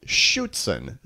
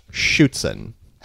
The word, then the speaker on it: Schützen: Today, it's called Schützen? – Schützen. It's a German word, but I believe it's pronounced Schützen.